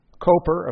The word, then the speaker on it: Cowper: Listen to the words of William Cowper. Just a biographical footnote here. Cowper,